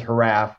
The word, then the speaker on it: HRAF: So the Human Relations Area of Files, which we refer to as HRAF, which is pronounced like giraffe, is a longtime agency at Yale University, which specializes in cross-cultural research.